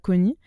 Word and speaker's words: Koni: Elle m'a appelée Koni. Je ne sais pas bien le prononcer en baganté. Ça vient du baganté.